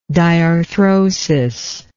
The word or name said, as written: diarthrodial